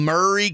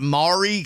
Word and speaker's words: Maury: Columbia is in Murray County, not Maury County.